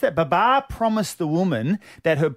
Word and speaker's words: Babar: The Pakistan captain, Babar Azam, I hope I'm saying that correctly. – Babar, yep.